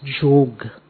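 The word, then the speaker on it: yog: And yoga, the real pronunciation is jog.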